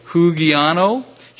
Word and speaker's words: hugiano: The Greek word is H-U-G-I-A-N-O, hugiano, hugiano. So I expect you to pronounce that correctly, too.